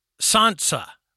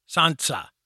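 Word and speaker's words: tsantsa: And that practice is called, I'm going to mispronounce it, I'm going to try, Santsa. It's T-S-A-N-T-S-A, Santsa.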